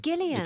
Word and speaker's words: Gillian: It's Gillian. – It's a hard G.